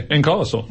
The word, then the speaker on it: ankylosaur: And this is an ankylosaur, if I pronounced that correctly. – Yeah, ankylosaur.